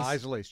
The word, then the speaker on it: Isley's: Is that how you pronounce that, Isley's? – Isley's.